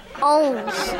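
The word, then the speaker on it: onze: Now, for our French listeners, how do you say 11 En français. – 11? 11? – It's a trick question. – Ohms. – Yeah, something like ohms.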